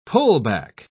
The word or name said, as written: pullback